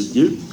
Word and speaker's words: Die: dans la ville de Die, hein, je crois qu'on doit prononcer Die,